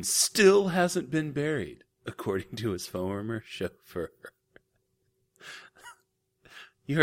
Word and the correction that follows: chauffeur: That is exactly how it sounded, including my mispronunciation of the word chauffeur, like I just did again. Chauffeur. Anyway,